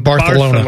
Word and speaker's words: Barcelona: Well, that's the way you're supposed to say it, is Barcelona. – Barcelona. Yeah. Was it The Internship? – So you're supposed to pronounce the soft C is supposed to be Barcelona.